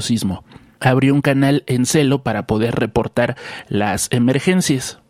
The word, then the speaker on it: Zello: Esta aplicación que se llama Celo, que se escribe Z-E-L-L-O, está disponible para iOS, Android, Windows Phone y también para la PC de escritorio.